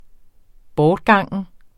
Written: bortgangen

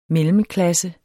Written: mellemklasse

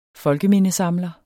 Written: FOLKEMINDESAMLER

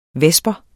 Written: vesper

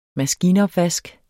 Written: maskinopvask

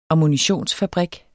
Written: ammunitionsfabrik

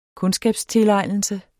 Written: kundskabstilegnelse

